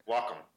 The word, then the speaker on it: Wacom: No, the uh, Wacom.